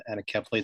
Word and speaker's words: anencephaly: My wife's from Australia, and we were living in Australia for a number of years, and our first child died of a condition called anencephaly, or some pronounce it anencephaly.